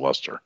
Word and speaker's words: Leicester: That's how you pronounce that. I know it looks like Worcestershire sauce or with an L in front, but it's not. It's pronounced Leicester.